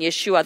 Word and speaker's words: Yeshua: So by following Yeshua, which is his real original name pronunciation, Yeshua, the Savior, following Yeshua and being yoked with him, we can enter that rest.